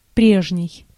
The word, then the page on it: former: American English; /ˈfɔɹ.mɚ/